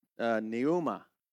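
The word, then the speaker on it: pneuma (πνεῦμα): All right, so the Greek word translated wind is pronounced pneuma, okay?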